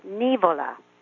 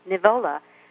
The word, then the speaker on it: Nivola: And in Italian, one would pronounce the name Nivola with the accent on the first syllable. But in fact, in Sardinia, it's pronounced Nivola the way you would pronounce it in America.